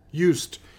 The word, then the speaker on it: used: Aren't you used? – USED 어떻게? 그 발음 하기 어려우니까 어떻게 해요? – Used. – This pronunciation is this. – Used. – 그 T 소리 나와야 돼요.